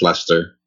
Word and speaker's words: Leicester: And by the way, just so that people know, a common misconception is that Leicester is pronounced Leicester, but for some reason I have no idea why. It's actually pronounced Leicester.